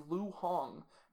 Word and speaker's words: Lu Hong: And the fifth is Lu Hong.